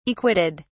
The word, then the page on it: acquitted: British English